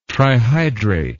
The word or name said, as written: trihydrate